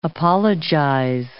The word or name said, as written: apologize